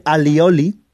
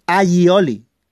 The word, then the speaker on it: allioli: La salsa aioli todo el mundo dice alioli, es aioli que viene de ajo y aceite. Ay es ajo, que se escribe A-L-L, ay, y oli, que significa ajo y aceite.